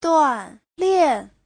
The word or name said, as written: duan4lian4